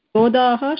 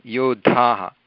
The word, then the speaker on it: योद्धाः: योद्धाः, अत्र योदाः इति नास्ति, योद्धाः.